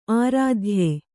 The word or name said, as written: ಆರಾಧ್ಯ